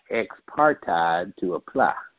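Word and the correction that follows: ex parte: Am I saying it right? Ex-partide? – Something ex-parte outside of counsel.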